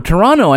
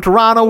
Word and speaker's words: Toronto: Oh, Toronto. I noticed you didn't pronounce that second T.